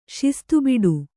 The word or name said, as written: ಶಿಸ್ತುಬಿಡು